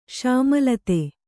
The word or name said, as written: ಶಾಮಿಲಾತು